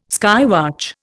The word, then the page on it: skywatch: Indian